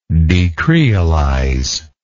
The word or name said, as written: decreolization